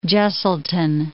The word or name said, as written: Jesselton